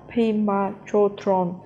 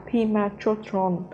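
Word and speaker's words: Pema Chödrön: Khi chị trích dẫn lời Pema Chodron, chị đã phát âm tên chị ấy là Pema Chodron. Cách phát âm đúng phải là Pima Chotron.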